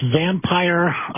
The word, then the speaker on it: Vampyre: Evidently, it's Vampire, that's spelled V-A-M-P-Y-R-E, Vampire Cosmetics.